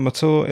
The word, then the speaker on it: Mathur: My family name, Matour, is spelt M-A-T-H-U-R.